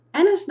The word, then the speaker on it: Anne: Anna's name is often pronounced Anne Frank, but her full name was actually Annalise, and in Europe it would be pronounced Anna. So out of respect, I'll try and use the traditional pronunciation of her name.